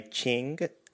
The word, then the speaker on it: Qing: This was created by Qing. It is spelled Q-I-N-G, and they did such an amazing job on this one.